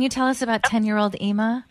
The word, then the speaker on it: Ima: And Robin, can you tell us about Ima, I-M-A? Is that how you say her name? – It's Ima,